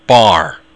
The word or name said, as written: barr